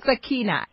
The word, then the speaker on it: Sakina: Sakina. It's Sakina. for sugar. A K I N A.